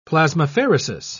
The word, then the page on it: plasmapheresis: British English